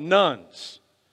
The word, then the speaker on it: Nones: They're called the nuns, or the duns. You have to spell it right, okay? So the nuns, N-O-N-E-S, people who self-identify as atheist or agnostic, as well as those that would say their religion is nothing in particular, okay?